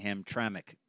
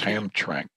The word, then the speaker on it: Hamtramck: A lot of people have a hard time saying Hamtramck, don't they? – That's right. Well, you kind of want to just say two syllables, Hamtramck. – Yeah. – Where it, but it's Hamtramck.